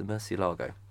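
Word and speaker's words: Murciélago: I always thought it was the Murcielago, but it's apparently, according to our pronunciation guide, it is the Murcielago.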